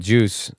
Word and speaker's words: KJUS: He's like, it's called Juice. It's spelled KJUS.